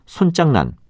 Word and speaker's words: 손장난: 오늘 말씀드린 여러 표현 중에는 두 번째 음절의 발음이 장으로 나는 것도 있었고 짱으로 나는 것도 있었는데 말장난, 물장난, 불장난은 모두 장으로 발음하고 손장난, 붓장난은 모두 짱으로 발음한다는 것도 함께 기억해 두시기 바랍니다.